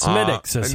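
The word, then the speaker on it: Smithwick's: How do you spell that? – Smithwick. – Oh, so there is a W in it. – Yes. – All right. So but it's Smithix.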